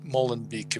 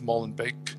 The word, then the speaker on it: Molenbeek: And that guy was also from Molen, Molenbeek, if I'm pronouncing it correctly, that suburb of Brussels, which mm.